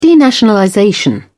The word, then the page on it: denationalization: British English